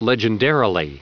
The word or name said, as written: legendarily